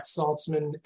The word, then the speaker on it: Saltzman: My name is Matt Saltzman, S-A-L-T-Z-M-A-N.